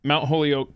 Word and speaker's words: Holyoke: So you went to Mount Holyoke College. – Yes. – Wait, did I actually pronounce something correctly for a change in this podcast today? – Good, you nailed it.